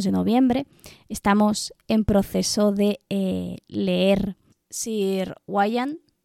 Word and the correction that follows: Gawain: no, Gawain, ya lo está pronunciando mal,